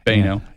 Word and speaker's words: Boenau: It's spelled B-O-E-N-A-U. – Right. – But you pronounce it Baino. – Baino, yeah.